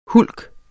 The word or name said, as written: hulk